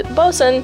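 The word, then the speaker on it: boatswain: It is pronounced bosun, even when it is...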